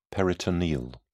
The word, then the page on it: peritoneal: British English